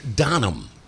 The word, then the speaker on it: Donum: Well, Donum. Doesn't sound Latin, does it? No, Donum. – It's the California pronunciation.